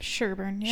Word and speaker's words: Sherburn: And I think I'm saying that town right. – Can you spell it? – S-H-E-R-B-U-R-N. – Yeah, Sherbourne. Yeah.